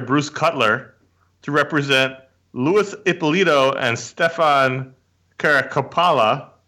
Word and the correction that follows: Caracappa: I said that wrong. Caracappa.